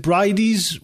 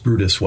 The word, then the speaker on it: Bryde: That's right, because I've read where you thought there were a species called, I might get this wrong, is it Brides whales? – It's pronounced Brutus whale. – Oh, the B-R-Y-D-E, is that pronounced? – Yes, yeah, I think that's it's a Scandinavian pronunciation.